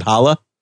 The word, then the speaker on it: Hola: Oh, if you could watch it, you have to be in Canada, but if you have Hola, or I think you like to pronounce it Hala.